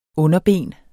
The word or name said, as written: underben